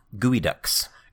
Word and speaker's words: Geoduck: Up next, the Evergreen State Gooey Ducks. – Gooey Ducks? – Gooey Ducks. So it's spelled Geoduck, G-E-O-D-U-C-K, but it's pronounced Gooey Duck.